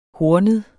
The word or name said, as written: hornet